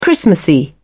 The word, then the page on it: christmassy: British English